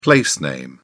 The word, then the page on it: place name: British English